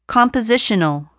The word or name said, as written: compositional